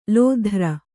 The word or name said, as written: ಲೋಧ್ರ